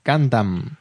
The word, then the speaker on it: Gundam: Su pronunciación es Gundam, con A.